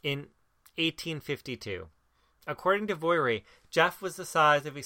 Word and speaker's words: Gef: According to the Irvings, a creature named Jeff, G-E-F, because apparently Jeff is pronounced the same way as Jif.